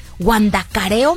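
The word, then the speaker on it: Huandacareo: ¿Es con H? ¿Guandacareo? ¿Sí se pronuncia así? Guandacareo, Michoacán. ¡Guau!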